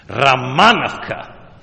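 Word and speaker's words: Romanovka: And then we were in Ramanovka, accent on the second syllable, very difficult to say for an American. Ramanovka.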